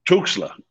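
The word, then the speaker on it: Tuxtla: Is that how you pronounce it? – Tuxla. Oh. Yeah, so San Andreas Tuxla.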